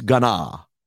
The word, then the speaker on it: Gunna: To say his name right. It's Gunna.